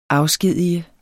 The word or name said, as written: afskedige